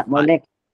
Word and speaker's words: Mwai Lek: I remember seeing a little video not too long ago on your Instagram, and I might get the name wrong. I don't know if it was Mwai Lek. – Yeah, Mwai Lek. – Is that right? – Yeah.